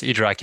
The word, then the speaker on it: IDRAC: Yes, IDRAC in France.